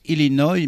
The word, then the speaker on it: Illinois: On prononce Illinois, mais ça vous pouviez pas le savoir naturellement.